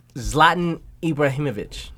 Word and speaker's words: Zlatan Ibrahimovic: Zlatan Ibrahimovic."